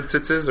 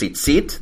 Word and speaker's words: tzitzit: Well, okay, because all of that is mostly Yiddish pronunciation, so in Yiddish, like the final T's are pronounced S's, or what would be a T in Hebrew, like mm-hmm. in standard Hebrew, is pronounced S in Ashkenazi or European standard Hebrew, and then they also put the accent on a different part of the word. Okay. So in Hebrew, it's tzitzit, that's the fringes on the side, mm-hmm.